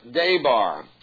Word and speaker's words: devar: So let's take this word, devar. The word be, for example, is pronounced like a V in the Hebrew language.